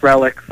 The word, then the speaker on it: Relix: Is it Relix or Relix magazine? – It's pronounced Relix.